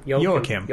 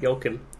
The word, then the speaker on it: Joachim: He says, it is a Scandinavian name, and it is not pronounced like the Latino counterpart. It is pronounced like Kim. – Joachim. – Joachim.